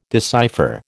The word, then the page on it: decipher: American English